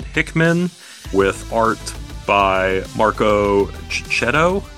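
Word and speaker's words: Checchetto: Marcus and, Cicchetto.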